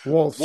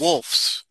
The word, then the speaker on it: Wolfs: Wolves. – Wolfs, not wolves.